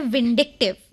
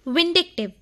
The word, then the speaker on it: vindictive: और हिंदी शब्द के बाद अब बारी है आज के इंग्लिश वर्ड की, जो है विंडिक्टिव, जिसकी स्पेलिंग होती है VINDICTIVE, विंडिक्टिव, जिसका अर्थ होता है प्रतिशोधी, बदला लेने वाला या फिर ईर्ष्यालु।